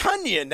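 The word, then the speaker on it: Tonyan: No, it's like Funyuns. It's yes. Tunyon. That's how you got to remember it.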